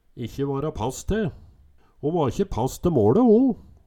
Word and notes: ikkje vara pass te: Numedalsmål

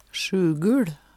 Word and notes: SjuguL: Numedalsmål

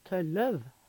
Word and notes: Tølløv: Numedalsmål

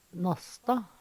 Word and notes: Nassta: Numedalsmål

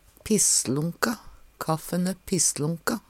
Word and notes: pisslonka: Numedalsmål

